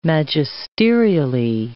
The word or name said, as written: magisterially